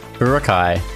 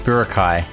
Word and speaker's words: Boracay: Boracay. – Boracay. It's pronounced Boracay. – It's not like B-A-R-R-U. – It's like Baraka. Kai.